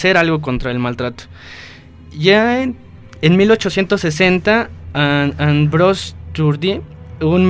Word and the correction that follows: Tardieu: ¿Qué es ese, el sinónimo, el síndrome de Tardieu, si mal no recuerdo? – Sí, precisamente.